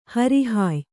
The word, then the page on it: ಹೊರಹಾಯು: hora hāyu